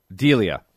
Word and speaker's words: Delia: Lakanen and Delia, is that how you say it? – Delia.